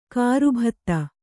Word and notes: ಗರ್ಬಿತ: garbita

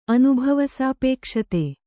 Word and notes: ಅನುಭವಸಾಪೇಕ್ಷತೆ: Kannada

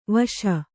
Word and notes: ವೇಷಿ: vēṣi

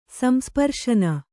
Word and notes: ಸಂಸ್ಪರ್ಶನ: Kannada

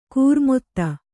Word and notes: ಖರಾಮತ್ತು: kharāmattu

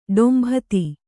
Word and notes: ಡೊಂಬಿತಿ: ḍombiti

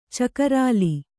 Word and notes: ಚಕರಾಲಿ: cakarāli; Kannada

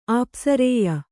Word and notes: ಅಪಾಶ್ರಯ: kannada

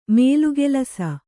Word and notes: ಮೇಲುಗೆಲಸ: mēlugelasa

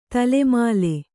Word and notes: ದಳಮಳ: daḷamaḷa; kannada

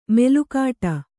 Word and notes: ಮೆಲುಕಾಟ: melukāṭa

